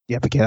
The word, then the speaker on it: Paquette: I believe that is the correct, Paquette, I believe that is the correct pronunciation.